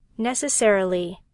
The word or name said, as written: necessarily